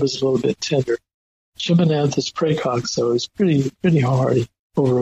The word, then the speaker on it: Chimonanthus praecox: Chimonanthus praecox.